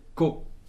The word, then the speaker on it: ko: Donc en espéranto, cette lettre s'appelle ko. K-O.